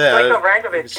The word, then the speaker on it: Stojko Vrankovic: Stojko Vrankovic, is that how you pronounce it? – Stojko Vrankovic, yeah.